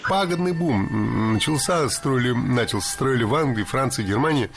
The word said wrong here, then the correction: начался: Много раз прозвучал глагол «начался», но это неверно. Правильный вариант – «начался», только так. Ударение в этом слове падает на возвратную часть «ся».